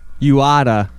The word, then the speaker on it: Uada: Uada. – Uada. – U-A-W-A? – No, you can't fucking spell that. – U-A-D-A.